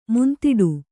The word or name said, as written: ಮುಂದಿಡು